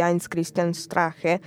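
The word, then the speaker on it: Heinz-Christian Strache: E, anche questo candidato, anzi, questo candidato si differenzia molto, diciamo, da invece il leader del movimento, quindi Heinz Christian Strache, che appunto quel... – Bravissima con la pronuncia, mia. Veramente straordinaria.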